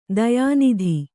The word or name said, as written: ತೋಯನಿಧಿ